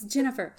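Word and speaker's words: Ginnifer: And it's Jennifer. – Jennifer. But, yeah. – It's a hard G. – It's a hard G. Like GIF.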